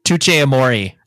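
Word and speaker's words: Touché Amoré: My number five is a little band named, I think it's pronounced Touch-A-More, I think. – Touch-A-More. – That's it.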